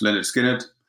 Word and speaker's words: Lynyrd Skynyrd: The three albums were Mark's choice, Lynyrd Skynyrd, pronounced Lynyrd Skynyrd.